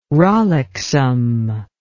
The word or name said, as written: rollicksome